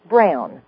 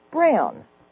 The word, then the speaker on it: Braun: So say it correctly for the crowd. – Brown. – Brown. – B-R-A-U-N. Brown. – See, I keep trying to say Braun.